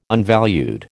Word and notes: unvalued: American English

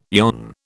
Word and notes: yon: British English

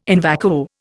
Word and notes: in vacuo: Indian

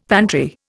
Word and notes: pantry: Indian

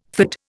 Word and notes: fid: Indian

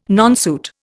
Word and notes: nonsuit: Indian

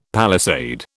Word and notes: palisade: British English

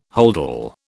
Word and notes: holdall: British English

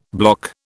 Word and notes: block: British English

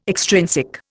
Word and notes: extrinsic: Indian

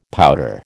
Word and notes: powder: American English

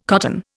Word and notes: cotton: Indian

